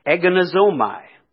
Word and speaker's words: agonizomai: Our English word fight here on the beginning of verse number 12 comes from a Greek word, agonizomai. And if you want to put this in your notes, I'll spell it for you. It's spelled A-G-O-N-I-Z-O-M-A-I, agonizomai.